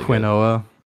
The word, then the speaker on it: quinoa: So the pronunciation is quinoa or quinoa, it says here. – Quinoa. Wow. Yeah. Interesting. – I guess both are legit.